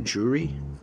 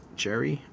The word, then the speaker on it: Jerry: Like, is it Jury? Jerry? I think I've been saying it wrong. – It's Jerry.